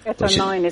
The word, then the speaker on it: Eter9: But you've also posted a link to this, and I'm not sure if I'll pronounce it right, Eter9, E-T-E-R, numeral 9. – Eter9 is how I've heard it pronounced.